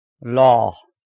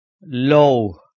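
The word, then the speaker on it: لوح: Should not be pronounced law. It is low.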